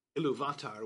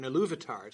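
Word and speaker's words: Ilúvatar: Again, normally it's the second to last syllable. So right. – Iluvatar would sort of sound right. But Tolkien puts the accents – in which are stress marks, so it's actually designed to be a pronunciation aid. So there's an accent on the U in Iluvatar to tell you that's the stress, because it's an exception to the rule. It's the second instead of the third syllable that's stressed.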